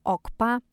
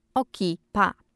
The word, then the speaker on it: OKPA: Wymawiane jest jako OKPA, OKPA, OKIPA. Dziesiąte miejsce zajmuje Skibidi.